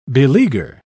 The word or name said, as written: beleaguer